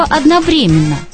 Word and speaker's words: одновременно: Вот слово «одновременно». Так его рекомендуют произносить, словари для работников радио и телевидения. А академические словари утверждают, что у одновременно есть близнец, который чуть-чуть отличается ударением. Одновременно, одновременно и одновременно равноправные варианты.